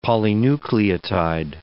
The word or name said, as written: polynucleotide